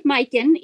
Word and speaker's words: Majken: It's pronounced Majken, even though it's spelled M-A-J-K-E-N, and that's because in Swedish, the letter I makes an if sound.